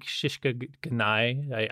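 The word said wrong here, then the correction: Shikata ga nai: I always mess up the pronunciation. – Shikata Gnai. Shishka Gnai better, though.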